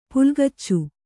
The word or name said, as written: ಪುಲ್ಗಿಚ್ಚು